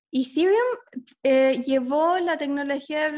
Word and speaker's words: Ethereum: Em, eh, cuéntanos de Ethereum, eh, así se pronuncia. Sí, Ethereum.